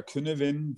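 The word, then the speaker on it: Cynefin: Ähm, ja, also im Prinzip könnte man das Canavian Framework ähm, oder Künevin, wie es im Deutschen auch oft ausgesprochen wird, ähm, ja hernehmen, um genau diesen Unterschied zwischen verschiedenen Arten von Veränderungen vielleicht mal zu verdeutlichen.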